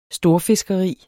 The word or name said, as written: storfiskeri